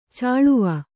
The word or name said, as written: ಚಲುವೆ